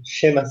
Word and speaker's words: Seamus: Y entonces conforma este equipo de enviados, el hermano Alfonso Lam, junto con un compañero de ruta que se llama Gemas Grace, que fue Gemas Grace, se escribe Seamus Grace.